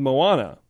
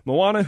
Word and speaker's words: Moana: Speaking of heroines in movies, as Frozen was a giant success, like $1 billion, Disney has just introduced a new heroine called Moana, I believe is how you pronounce it.